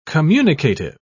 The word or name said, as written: communicative